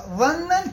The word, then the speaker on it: ظنا: ظنا كيف جاء أي كلمة ظن ومشتقا ظن كيف جاءت يعني أظفر ظنا كيف جاء يعني ظنا وين ما أجت هي ومشتقاتها، تقرأ بالظاء وليست بالضاد، سواء جاءت بمعنى الشك أو اليقين وسواء كانت اسما أو فعلا فلا بد أن تلفظ بالظاء وليست بالضاد.